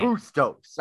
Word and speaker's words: Bustos: Like, you think like Spanish. Right. Bustos, I believe it's pronounced.